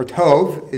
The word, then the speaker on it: Tob: So he was cast out, and so he lived in the land of Tob. Or Tov is probably how it's pronounced in Hebrew, and that means good, in the good land.